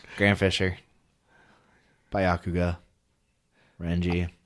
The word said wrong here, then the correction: Byakuya: I'm sorry, how the fuck did you just pronounce his name? – I don't even know. Byakugan? – It is Byakuya Kuchiki, sir. Kuchiki. And I will thank you to pronounce it properly.